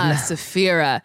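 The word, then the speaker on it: Sapphira: It helped me when RuPaul said, there's nothing to Safira but fear itself. That helped me say her name right. – Safira.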